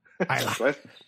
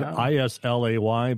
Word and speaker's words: Islay: Isla. Bless you. – Isla. Yeah, exactly. He's just clearing his throat there. – Yeah. Spelled Islay, but it's pronounced Isla. – The Y is silent.